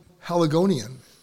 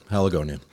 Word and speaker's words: Haligonian: But, I mean, you are a lifelong Haligonian. Did I say that right? – That's right, Haligonian.